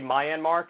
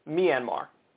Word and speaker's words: Myanmar: I always say Myanmar because that's how it looks like it's spelled to me. That's the phonetic spelling, but or phonetic pronunciation. But apparently it's Myanmar. That's the correct way to pronounce it.